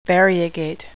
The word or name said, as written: variegate